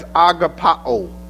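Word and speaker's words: agapaō: Again, spare you the pronunciation of these words, but this one has an ending on it that it sounds, I think the word would be pronounced as agapao.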